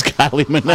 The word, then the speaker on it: Minogue: Kylie Minogue, but go ahead. – How do you say it? – That's Kylie Minogue.